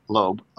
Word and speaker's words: Loeb: I think that's how it's pronounced. Loeb. O-L-O-E-B. – Yeah, it'd be Loeb.